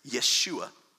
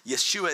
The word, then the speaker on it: Yeshua: What you might not know about Jesus is the name Jesus is not his real way you say his name. His name is Yeshua. Yeshua is actually also Joshua.